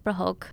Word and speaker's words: prahok: And so let's talk about prahok. Mm-hmm. Am I saying that right? – Oh, yeah. Prahok. Perfect.